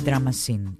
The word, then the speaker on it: Drama Scene: Drama Gain για πρώτη φορά μαζί. Η Drama Scene είναι η σωστή προφορά.